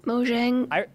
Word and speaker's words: Mojang: How the heck do you pronounce Mahjong? Is it Mahjong? Is it Mojang? Is it Mahong? I've heard all three.